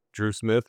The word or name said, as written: Dru Smith